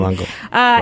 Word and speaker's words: galangal: Galangal root? Anybody? – Nope. – Oh, man. I am the queen of mispronunciation. – Galangal works.